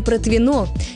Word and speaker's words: Протвино: Похожая история с названием «Протвино». Здесь ударение тоже всегда ставится на последний слог.